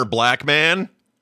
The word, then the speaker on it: Blackman: I didn't know Pussy Galore's real name was Honor Black Man. – Yeah. – Black Man. – I don't think it's Black Man.